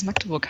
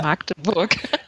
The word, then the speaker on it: Magdeburg: Genauso wie du es auch gut gesagt hast, dass es Magdeburg heißt und nicht Ja. – Magdeburg. – Ich habe gerade jemanden am Wochenende in der U-Bahn gesehen, der es genauso falsch ausgesprochen hat,